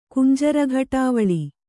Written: ಕುಂಜರಘಟಾವಳಿ

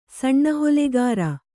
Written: ಸಣ್ಣಹೊಲೆಗಾರ

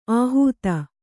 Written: ಆಹೂತ

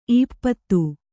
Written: ಈಪ್ಪತ್ತು

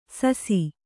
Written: ಸಸಿ

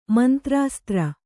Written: ಮಂತ್ರಾಸ್ತ್ರ

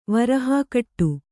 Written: ವರಹಾಕಟ್ಟು

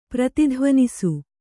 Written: ಪ್ರತಿಧ್ವನಿಸು

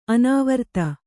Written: ಅನಾವರ್ತ

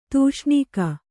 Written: ತೂಷ್ಣೀಕ

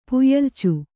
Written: ಪುಯ್ಯಲ್ಚು

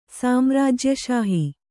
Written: ಸಾಮ್ರಾಜ್ಯಷಾಹಿ

